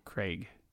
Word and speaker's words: Craig: It's like AIG. – Craig. – Yeah. – Craig. – Craig. – It almost sounds like Craig.